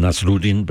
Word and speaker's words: Nasruddin: I always pronounce him Nasruddin, but that nobody cares because he's pronounced in so many different ways.